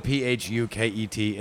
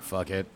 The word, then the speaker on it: Phuket: it's spelled Phuket. It's not fuck it. It's called Phuket.